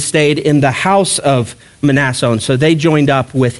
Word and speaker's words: Mnason: And that is the proper pronunciation, Mnason. The M is not silent. I wish it was. That would be a lot easier. But it is Mnason in the Greek.